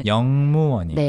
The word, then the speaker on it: 역무원: 근데 발음이 역무원이 아니고 영무원이. – 네, 영무원. – 영무원, 영무원이고 네.